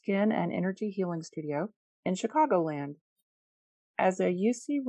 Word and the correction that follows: Usui: Reiki master, did I say that right? Usui. Usui Reiki master, Melissa guides her clients through deep mind, body, spirit, skin transformations.